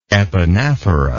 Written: epanaphora

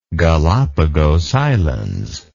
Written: Galapagos Islands

